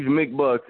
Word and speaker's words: McBuccs: Siege McBucks, M-C-B-U-C-C-S. – Don't leave no C out. You're going to get mad. – S-I-E-G-E-M-C-B-U-C-C-S.